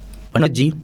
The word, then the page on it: पणजी: Kok; /ˈpənəɡiː/